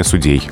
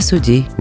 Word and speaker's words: судей: И именно в тех словарях, которые адресованы нам с вами, тем, кто сидит перед микрофоном, именно ударение судей. – Коллегия судей.